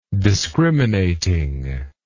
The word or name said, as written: discriminating